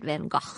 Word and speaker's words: Van Gogh: And if you're going to Amsterdam in the near future, the Van Gogh, that's how they say it, Van Gogh.